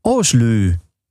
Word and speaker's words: Aslaug: En je moet het uitspreken als Oslu. Nou ja, oké. Nou ja, Oslu was dat dan dus met Take From Me.